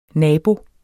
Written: N

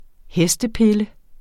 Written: hestepille

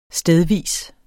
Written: stedvis

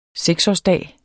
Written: seksårsdag

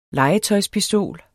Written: legetøjspistol

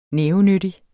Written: nævenyttig

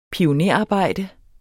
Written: pionerarbejde

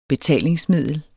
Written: betalingsmiddel

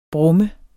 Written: brummer

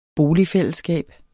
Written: boligfællesskab

